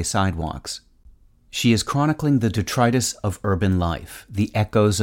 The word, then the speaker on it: detritus: If you use detritus in speech, remember to stress the second syllable, as you do in the words arthritis and bronchitis. Once you've mastered its meaning and pronunciation, you'll find that detritus is a term,